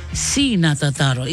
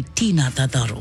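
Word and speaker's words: Ti na tataro: Si na Tataro. It's spelled T-I. You can't say Tina Tataro.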